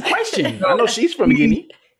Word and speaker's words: Guinea: I mean, specifically for you two, I know you guys are Muslim, and I'm not sure are both your families from Guinea. I hope I'm pronouncing that right. – Yeah, it is Guinea, but no.